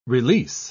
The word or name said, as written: release